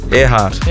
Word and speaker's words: Earhart: Earhart.